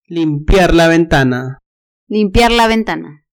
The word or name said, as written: limpiar la ventana